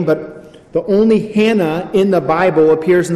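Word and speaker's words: Hannah (Ἅννα): But it's on the heels of this prophecy that we meet a woman named Anna. Now, it's interesting that we call her Anna because even in the Greek, the proper pronunciation of her name is Hannah.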